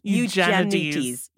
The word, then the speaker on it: Eugenides: Eugenides? There you go. – Eugenides.